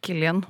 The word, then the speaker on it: Cillian: Mener jeg har googla det at det er Killian, det er Killian, fordi jeg har gjort Killian, samme feil.